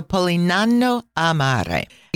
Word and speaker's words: Polignano a Mare: And just in case you're Googling this, trying to figure out what I just said, when you see the letter G in the middle of a word, you don't pronounce it. So this name is spelled Polignano, and then Amare. So Polignano Amare.